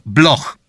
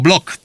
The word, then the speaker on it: Bloch: Jan Gottlieb Bloch, albo Blok czasami tak się wymawia, z Radomia, związany następnie z Warszawą, Znowu, finansista, przemysłowiec, nazywany królem kolei żelaznych.